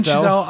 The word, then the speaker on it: Chazelle: How do you say his last name? Chazelle. You want to say? – Yeah, that sounds right. – Yeah, Damien Chazelle.